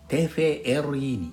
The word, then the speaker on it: TVRI: インドネシア語では V の発音は F の発音に置き換わりますので TVRI はテフェエルイ